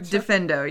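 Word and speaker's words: Diffindo: Diffendo, yeah.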